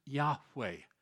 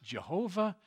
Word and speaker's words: Yahweh: It is best pronounced perhaps Yahweh. You've heard it pronounced perhaps in the past Jehovah. It is not possible to pronounce it Jehovah in the Hebrew language. They don't have a J or a V in their alphabet.